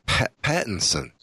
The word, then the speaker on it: Pattinson: I could very well be wrong about Robert Peterson. Pattinson. Maybe I'll get his name right.